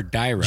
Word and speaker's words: gyro: Did you say gyro or dyro? – Gyro.